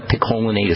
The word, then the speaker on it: picolinate: As it turns out, zinc acetate and zinc gluconate work similarly, but I prefer zinc picolinate. That's my favorite form of zinc as a nutritional supplement. Picolinate is spelled P-I-C-O-L-I-N-A-T-E. Picolinate. Also, I like zinc monomethionine. That second word is spelled M-O-N-O-M-E-T-H-I-O M-O-N-I-N-E.